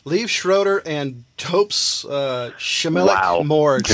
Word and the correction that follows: Liev Schreiber: Liev Schreiber.